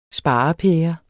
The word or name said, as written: sparepære